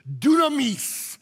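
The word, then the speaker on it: dunamis (δύναμις): And I love that word power because it is pronounced in Greek dunamis.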